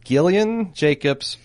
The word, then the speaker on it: Gillian: And they're spelled exactly the same. – Yes. – But one has a soft G and one has a hard G. – Yeah, they do. – Gillian Jacobs,